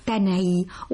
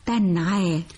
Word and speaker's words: تنعى: أذكر فعلا أنني صوبت لك بالأمس كلمة تنعى، وأنا استوعبت الدرس ولن أنطقها بعد اليوم تنعي، وسأقولها تنعى.